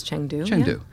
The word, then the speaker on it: Chengdu: Chengdu. – I think it's Chengdu.